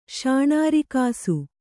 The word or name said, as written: ಶಾಣಾರಿಕಾಸು